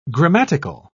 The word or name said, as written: grammatical